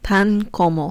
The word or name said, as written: tan como